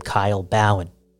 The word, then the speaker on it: Kyle Bhawan: My name Kyle Bhawan.